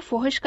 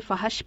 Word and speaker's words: فحش: لکھتے ہیں، فحش کا تلفظ فے کے اوپر پیش کے ساتھ ہوتا ہے جبکہ اسلام آباد کے ایک نشریے میں اسے فحش پڑا گیا یعنی فے پر زبر کے ساتھ. – آپ نے درست کہا یعقوب صاحب، درست تلفظ فحش ہے.